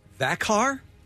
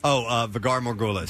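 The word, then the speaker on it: Valar Morghulis: Thakhar? Is that how it's pronounced? Morghulis? – Vhagar Morghulis.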